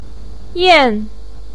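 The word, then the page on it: yan: tone 4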